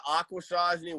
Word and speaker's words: Akwesasne: and I believe one of the teams was Akwesasne. Did I say that correctly? – Yeah, the Akwesasne Warriors.